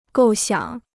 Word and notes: 构想: gòu xiǎng

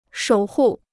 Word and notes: 守护: shǒu hù

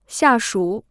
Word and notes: 下属: xià shǔ

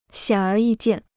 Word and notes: 显而易见: xiǎn ér yì jiàn

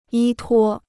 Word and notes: 依托: yī tuō

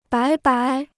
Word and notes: 白白: bái bái

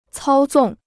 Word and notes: 操纵: cāo zòng

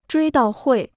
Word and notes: 追悼会: zhuī dào huì

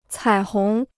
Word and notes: 彩虹: cǎi hóng

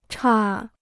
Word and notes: 岔: chà

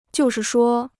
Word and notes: 就是说: jiù shì shuō

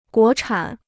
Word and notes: 国产: guó chǎn

